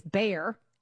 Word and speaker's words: Bayer: Bayer, we pronounce it here in the States, Bayer.